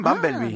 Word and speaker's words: Bumblebee: Vamos con la superproducción estadounidense de la semana, a ver si soy capaz de pronunciarlo bien, porque debería ser algo así como Bumblebee. – Muy bien, Bumblebee.